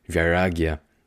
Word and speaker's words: vairagya: Vairagya, you hear that? Yeah, you think of Viagra. It's not Viagra. It's a Sanskrit word, vairagya, for detachment.